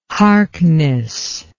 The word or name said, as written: Harkness